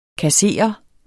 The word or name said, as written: kasserer